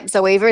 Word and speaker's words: Zoeva: The Zoeva. Is that how you say it? Yeah, Zoeva is fantastic.